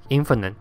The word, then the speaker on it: infinite: 之类的开头很长的时候，它代表的意思是相反的，所以我们可以看到 finite 是有限的，而跟有限相反的 infinite 就是无限的。大家在发音上也要特别注意，finite 的 fi 是发 fi，但是 infinite 的 fi 是发 f。